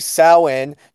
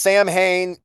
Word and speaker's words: Samhain: Yep, and then on to our next topic, we'll be previewing NWA Samhain. No, this is not pronounced Sam Hain. I was even pronouncing it Sam Hain for the longest time. It's Samhain.